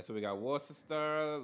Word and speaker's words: Worcester: It's Worcester.